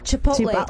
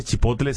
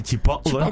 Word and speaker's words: Chipotle: I know, Chipotle. – Claro, no se dice Chipotle, se dice Chipotle.